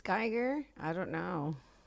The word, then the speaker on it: Geiger: What does a giga counter measure? – Ebro. – Laura. – Ebro. – That measures I could be saying it wrong. – Megabits, megabytes, gigabytes. – Geiger. – Oh. – That's wrong. – Geiger. – Geiger. – G-E-I-G-E-R. – That's Geiger.